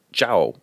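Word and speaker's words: Zhou: Is pronounced Zhao, but it's Z-H-O-U.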